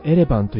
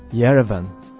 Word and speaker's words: イエレバン: 日本ではエレバンと言いますが、イエレバンと発音していましたね。